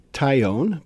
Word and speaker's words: Tyone: So we walked over and took a look at it, and so we have, now it's Tyone, which is easy to mispronounce, but it's T-Y-O-N-E, right? – Mm-hmm.